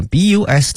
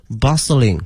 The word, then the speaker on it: bustling: 夹在这些高楼大厦之间图书馆哈，后面用的是 city bustling 哈，这个 bustling 哈非常熙熙攘攘哈，特别繁忙这个词 bustling，同学们注意一下这个 t 呢是不发音的 bustling 哈，陆家嘴 financial hub，这里面 hub 呢是指一个中心哈，一个枢纽哈， financial hub。